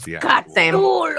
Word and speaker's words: Cthulhu: How are we pronouncing? Is it Cthulhu? – Cthulhu. – It is debated. – It's not debated. People just say it wrong all the time. Then I get confused. Which it's Cthulhu.